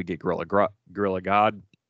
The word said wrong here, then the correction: Grodd: Um, Grodd.